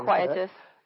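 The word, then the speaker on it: iniquitous: Horrendous, dreadful, hideous, foul, nauseating, sickening, vile, villainous, nasty, ghastly, odious, loathsome, shameful, infamous, nefarious, inquietous. – Mm-hmm. – I think that's the right way to say it. Yep, inquietous.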